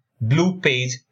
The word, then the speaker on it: Bleupage: It's spelled as BLEUPAGE, blue page review.